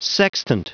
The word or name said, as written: sextant